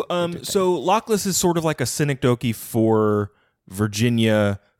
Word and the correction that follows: synecdoche: Is that how it's pronounced? – I think it was synecdoche. I think you got it, but you put the stress on the wrong side.